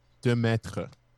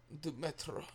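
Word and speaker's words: Duomètre: It's the Jaeger Dual Metre, I think is how you pronounce it. It would be this one. – Oh, yeah. Demetre. – Demetre. Yeah. Yeah.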